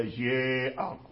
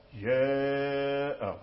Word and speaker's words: جاء: ولهذا ننبه دائما على هذا الأمر هذا، لأن الطلبة يقرؤون جيء جيء، هذا كسر خالص، إذن أين الإمالة؟ ليست هذه إمالة، فهذا كسر، هذا كسر، والإمالة ليست كسرا. إذن لا بد أن تبقي الفتح وأنت تنطق بالكسر، لا بد أن تبقي الفتح وأنت تنطق بالكسر، فتكون الفتحة بين الكسرة والفتحة، والآلف بين الهمزة بين الياء والآلف، هكذا جاء جاء، هكذا اسمع جاء، إمالة صغرى جاء جاء، إمالة كبرى جاء، إمالة صغرى جاء، هكذا.